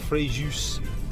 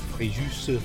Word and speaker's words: Fréjus: It is Fréjus, and you pronounce the S. We were saying in our family Fréjus for a long time, but it's Fréjus.